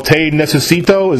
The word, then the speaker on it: Te Necesito: Is it called Te Necesito? Is that correct? Yes, you did. You said it right, brother.